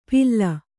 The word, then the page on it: ಪಿಲ್ಲ: Kannada